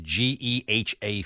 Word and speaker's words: GEHA: Yeah, that's how you pronounce it. Geha Field at Arrowhead Stadium.